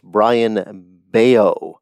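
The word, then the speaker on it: Bryan Bello: But you look at this guy's stats, and you spell his name B-R-A-Y-A-N, and his last name is spelled B-E-L-L-O. It's pronounced Brian Bello.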